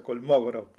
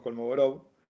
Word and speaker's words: Kolmogorov: Em, después, a mí hay, un, matemático que, yo admiro mucho, que es, eh, ruso, en ruso se, pronuncia Kolmogorov, pero nosotros acá por acá le decimos Kolmogorov.